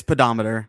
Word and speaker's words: pedometer: I think it's pedometer. – Pedometer. – Like, almost like it's an A, almost like it's P-A-D.